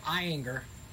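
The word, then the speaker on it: Ayinger: It's called Eyinger. – Eyinger. – Eyinger. – Eyinger. – Eyinger, yeah. – Eyinger. And it's a... – Germans do not have a soft G like we do.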